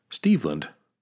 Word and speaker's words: Steveland: Steveland. Rhymes with Cleveland? – Stevie Wonder.